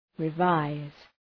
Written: revise

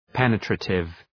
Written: penetrative

